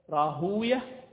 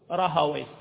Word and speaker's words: راهويه: في هذا اللفظ لك أن تقول راهوية ولك أن تقول راهوية، هذا اللفظ وأمثاله من هذه الألقاب، مثل سيبوية ومثل ماذا حمدوية ومثل نفطوية ومثل عرفت هكذا.